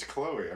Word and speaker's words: Chloe: People always put my name C-H-O-L-E or C-O-L-E. – How is it spelled? – Cole. Chloe. – It's Chloe. I mean, that's C-H-L-O-E.